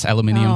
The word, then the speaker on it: aluminium: And, is it in, don't the Brits say aluminium? – Yes, aluminium.